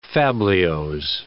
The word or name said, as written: fabliaux